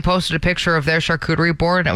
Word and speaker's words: charcuterie: I'm a charcuterie snob is what I am. – A charcuterie snob. You even say it properly.